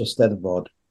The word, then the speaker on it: Eisteddfod: I want to say the Eisteddfod. I know I'm pronouncing that wrong. – Exactly right. That's exactly right. It came out of the Welsh Eisteddfod.